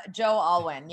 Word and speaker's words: Joe Alwyn: Joe Alwyn.